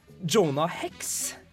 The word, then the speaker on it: Jonah Hex: Jonah Hex igjen, det er riktig.